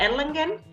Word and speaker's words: Erlangen: Bueno, básicamente en, estos momentos estoy radicada en Erlangen, es una ciudad muy pequeña, pero es una ciudad universitaria.